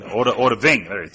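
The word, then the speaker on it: Odahviing: I had to learn a shout called Odvat. I can't even pronounce this word. – Odaving. – Odaving. Thank you very much.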